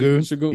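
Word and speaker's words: Şengün: Sengun.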